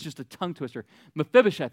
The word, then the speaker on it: Mephibosheth: His name is Mephibosheth. Mephibosheth. Mephibosheth. All right, you got that? Mephibosheth. I said it right at least five times.